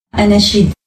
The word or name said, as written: Anachid